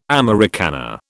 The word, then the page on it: Americana: British English